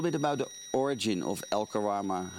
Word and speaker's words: El Karama: El Karama Lodge is a private wildlife Conservancy.